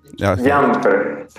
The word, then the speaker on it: Jeanfer: Sí, Janfer.